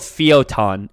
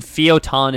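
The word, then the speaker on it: Fio'taun: So it's the city is called Fiotan, and Fiotan is spelled Fio apostrophe T-A-U-N. So F-I-O apostrophe T-A-U-N.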